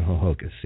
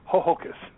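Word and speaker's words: Ho-Ho-Kus: A Hohokus. Is that correct, Peter? – It's actually an Indian word, and yeah, they named the town Hohokus. Yep. – I was on the wrong syllable. I'm sorry. Hohokus. Hohokus.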